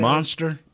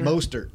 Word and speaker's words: Mostert: Monster? – He has 20. – 20. What did you say his name? Monster? – Mostert.